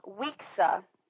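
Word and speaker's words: Wekesa: Weeksa? – Weeksa. It's W-E-K-E-S-A.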